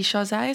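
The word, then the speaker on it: Chasez: Was it JC Chazé? Is that how you say his name? – Yeah.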